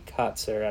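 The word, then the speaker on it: Kotsur: And I think the father character, I believe his name is Tony Kotzer. I'm not sure if I'm pronouncing it right, but... – That's how I've heard it pronounced.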